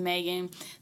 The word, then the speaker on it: Maeghan: So, guys, my Instagram, which is what I'm on 24/7, that's really my only biggest social media platform, is at Move With Megan. That's MOVE. And then WITH, and my name is spelled very different. It's MAEGHAN. I'll say it again, MAEGHAN. Yeah. Move With Megan.